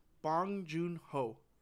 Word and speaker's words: Bong Joon Ho: It is Bong Joon Ho.